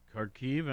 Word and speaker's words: Kharkiv: Yesterday it was Kharkiv, I think, is how it's pronounced.